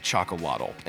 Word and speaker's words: Xocolatl: Now, before you let your mind start playing tricks on you, the name is pronounced Chocolatl, and it starts with an X, so don't be confused and just track along and you, will be just fine.